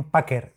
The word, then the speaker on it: Packr: Y le escuché a Christian en el podcast de Mac eh, la aplicación Packer. Se escribe P-A-C-K-R.